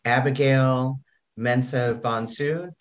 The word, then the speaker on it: Abigail Mensah-Bonsu: Today, I have Abigail Mensah-Bonsu.